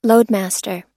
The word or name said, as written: loadmaster